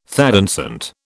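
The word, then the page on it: thé dansant: British English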